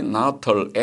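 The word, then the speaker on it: Nuttall: 클레이 나털 박사는 클레이 나털 N-U-T-T-A-L-L 그 스펠링 보니까 클레이 나털 박사는 다음과 같이 말했습니다.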